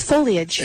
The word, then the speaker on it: foliage: The leaves, the word that nobody knows how to pronounce. Is it foliage? Is it foliage? Is it foliage? – It's foliage.